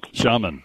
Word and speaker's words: shaman: How do you guys pronounce it? Is it Ride of the Shaman or the Shaman? – Shaman. – Okay, we'll go with the softer A then on that one.